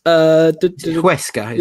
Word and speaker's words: Huesca: Huesca, is it? – Yes, that's it.